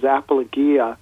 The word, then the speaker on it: apologia: I've heard it pronounced two different ways, but I think the proper way to pronounce it is apologia, and it means defense or reason.